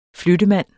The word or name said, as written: flyttemand